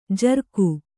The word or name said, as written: ಝುರುಕಿ